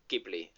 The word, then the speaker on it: Ghibli: Ghibli.